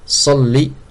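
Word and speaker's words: salli (صَلِّ): Donc il faut bien le prononcer, Allahumma salli, c'est un sad, et le lem il est doublé sans la prolongation. C'est interdit de prolonger. Donc on ne dit pas salli, ça change le sens.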